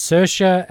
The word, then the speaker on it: Saoirse: She once said in an interview, and this is what stuck, she says it's Saoirse as in inertia. – Ah, thank you. Saoirse, yeah.